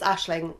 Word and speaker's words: Aisling: Yes, it's Ashling, and it's very important. It was very important to you, Ashling, to pronounce it right.